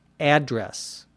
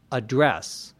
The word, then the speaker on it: address: Notice the pronunciation there. If it's a noun, it's address. If it's a verb, it's address.